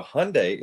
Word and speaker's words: Hyundai: This Korean Hyundai. – Hyundai. Okay. So Hyundai is running an ad campaign where they are pointing out it's Hyundai.